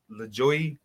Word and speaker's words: Lajoie: All right, but my number one, and I'm going to butcher this guy's name, John, it's L-A-J-O-I-E. – L-A-J-O-I-E. – Yeah. LaJoy. Everyday Normal Guy. – John LaJoy.